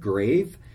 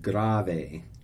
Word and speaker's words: grave: Sometimes it looks like the word grave, but it's actually pronounced grave, grave. So just like sound it out.